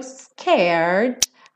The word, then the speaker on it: scared: Both a little scared. Aqui também, scared, a gente não pronuncia a letra E. Então, enrolou a língua para o R, scared, põe o D, scared.